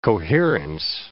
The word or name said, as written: coherence